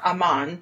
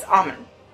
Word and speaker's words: Aumann: and I found out that it's not Aumann, it's Aumann, according to them.